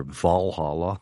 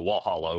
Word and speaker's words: Valhalla: Valhalla, but it turned into Walhalla over here. – Yeah, good guess on my part, because Scandinavians pronounce their V's with W's.